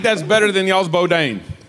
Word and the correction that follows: boudin: It's Boudin. Boudin. – And he said it wrong.